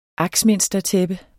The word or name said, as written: axminstertæppe